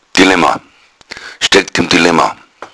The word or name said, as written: dilemma